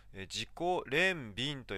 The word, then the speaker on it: 自己憐憫: これちょっと難しい漢字なんですけれども、自己憐憫と読みますね。